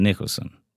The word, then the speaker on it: Nicholson: Nicholas? Mm-hmm. Nicholson. I say that right.